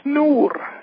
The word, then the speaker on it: nur: It's a Chien Canadian French, C-H-I-E-N. And yep. – French Michif, it's S-H-A-E-N. – Oh, yeah. – Okay. Like a bear is ours, it's spelled like ours. It's O-U-R-S, and French Michif, it's Noor, N-U-R.